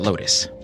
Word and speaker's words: BLK LT$: It's spelled B-L-K LT dollar sign. But LT dollar sign, you pronounce that Lotus. Good to know. Following that, we had Monsoon, M-O-N-S-U-N-E, with a track called Out of My Mind.